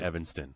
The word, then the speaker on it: Evanston: Evanston. – Yeah. – At least I said that right. – Yes. – Evanston.